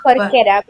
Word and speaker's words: Jorquera: I don't know if I pronounced that correctly. – Yeah, it's Jorquera, but it's okay.